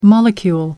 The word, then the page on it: molecule: American English